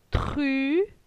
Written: Tru

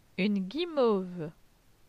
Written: Une guimauve

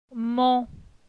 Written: Mon

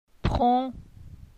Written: Pron

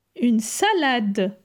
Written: Une salade

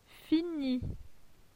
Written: Fini

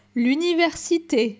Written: L'universite

